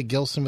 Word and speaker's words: Gilson: Did I say your last name properly? Gilson with a hard G? – Yes, that's right. You did.